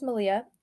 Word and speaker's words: Melia: So first off, my name is Malia. It's spelled M-E-L-I-A.